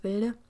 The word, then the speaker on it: Wilde: If you want more of me, if you want to see my lovely face, I do have an Instagram it's at Leni Wilde or Wild at Leni Wild. I don't know. Like my my surname actually is German, so it's Wilde, but like you know, it's spelled Wild.